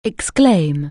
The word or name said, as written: exclaim